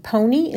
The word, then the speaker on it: Ponie: Her nickname is Pony. Is that how you pronounce it? – Yeah. P-O-N-I-E.